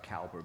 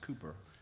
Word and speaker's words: Cowper: William Cowper was, I believe that's how you pronounce his name, it was like Cowper, but it's, I believe it's pronounced Cooper, was a man who struggled with depression.